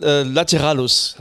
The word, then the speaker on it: Lateralus: Das Album heißt übrigens Lateralus, heißt das Album. Ich hätte vorher, glaube ich, das falsch ausgesprochen.